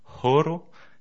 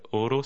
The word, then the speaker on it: horo: Le H, on l'aspirera, donc on entendra le H, par exemple, pour dire l'heure, ce sera horo, et non oro, si on oublie de le prononcer, l'heure devient or.